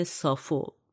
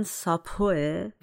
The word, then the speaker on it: سافو: سافو تلفظ انگلیسیش ظاهرا ساپوه ولی تو فارسی سافو ترجمه شده